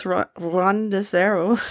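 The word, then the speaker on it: ranuseru (ランドセル): So you did very good with ranuseru.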